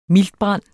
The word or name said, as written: miltbrand